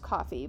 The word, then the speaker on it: Ko-fi: But with coffee, it's pronounced coffee, but it's spelled, I think it might have a dash in it.